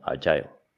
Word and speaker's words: agile: la pronunciación británica de, de agile es más como agile, de hecho.